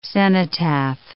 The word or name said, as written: cenotaph